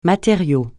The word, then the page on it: matériau: francais